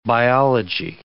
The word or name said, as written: biology